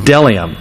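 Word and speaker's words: bdellium: Is it delium? I think the B is silent.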